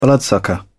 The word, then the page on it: bloodsucker: British English